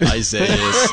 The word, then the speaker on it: Isaias: It is, Isaias.